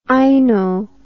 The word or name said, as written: aino